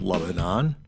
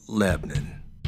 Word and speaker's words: Lebanon: Spelled Lebanon, but pronounced Lebanon. Anyway,